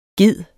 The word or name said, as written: GEDEN